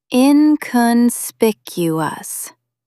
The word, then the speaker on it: inconspicuous: Stress the third syllable. Inconspicuous.